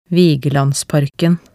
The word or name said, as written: Vigelandsparken